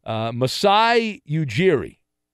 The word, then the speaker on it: Masai Ujiri: Masai Ujiri,